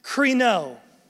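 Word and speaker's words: krino (κρίνω): The word that is used right here is the Greek word that's pronounced krino, that word judge.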